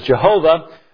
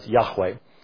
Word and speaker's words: Yahweh: It's called the Tetragrammaton in Hebrew, Yod Hey Wow Hey, which we slaughter in our hymnology as Jehovah, but was undoubtedly not pronounced as Jehovah. The best estimate that we can come up with in regards to ancient Hebrew is that the divine name was pronounced as Yahweh.